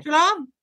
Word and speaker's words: Treloar: I would say it's Treloar College or Treloar, T-R-E-L-O-A-R. – Treloar, I don't know. – Treloar? – Treloar. Okay. Treloar. It sounds like you're saying goodbye. Treloar.